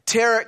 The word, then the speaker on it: tarot: Speaking to the dead, tarot cards, I said it right so many times before I got here. I always want to pronounce that last T.